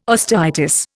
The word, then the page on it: osteitis: Indian